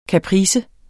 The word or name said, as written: kaprice